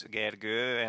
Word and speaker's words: Gergő: Yeah, my name also has like two lines on top of the O. It's Gergő, and yeah, most of the people I talk to can't really pronounce it.